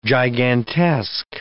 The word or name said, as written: gigantesque